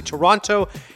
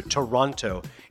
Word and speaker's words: Toronto: the Toronto International Film Festival. I pronounce it just the way non-Torontonians do, Toronto, even though we all know it's two syllables, Toronto. And the second T is always silent.